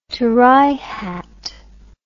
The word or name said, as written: terai hat